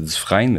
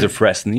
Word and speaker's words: Dufresne: C'est Dufresne, mais quand tu mm-hmm. prends en anglais, c'est mm-hmm. Dufresne.